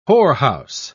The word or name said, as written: whorehouse